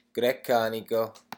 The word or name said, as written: grecanico